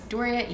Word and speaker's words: Doria: It is Doria Eve. – It is Doria, is spelled D-O-R-I-A, in case you don't know how to spell it. It's just Doris with an A at the end. – Yep, and then the woman who ruined all of our lives, Eve. E-E-E.